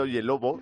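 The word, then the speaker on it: Oyelowo: El David Oye Lobo. Eh, la ha pronunciado perfecto.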